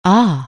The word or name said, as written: ah